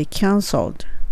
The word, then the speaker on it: cancelled: 歯の裏にしっかり当てて、 flight has been ここは hair の air を聞かせてちゃんと has been cancelled cancelled キャンセルにしてしまわないで cancelled L っていう発音がやっぱりちょっとね難しいと